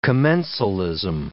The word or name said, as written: commensalism